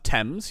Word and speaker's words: Thames: It's pronounced Thames, yeah, absolute native.